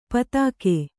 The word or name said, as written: ಪೈತ್ತಿಕ